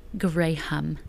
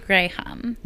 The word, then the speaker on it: Graham: No, I say Graham. – No, it's a very popular English name. – I say Graham. – Like Graham cracker. – Graham cracker, exactly. That's spelled the exact same way.